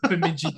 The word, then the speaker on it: Bemidji: The 2022 Mason Cup Final, Minnesota State University versus Bemidji State University. I hope I said that right. – Yeah, the powerhouse of Bemidji.